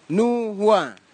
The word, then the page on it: nuwa: Kwamera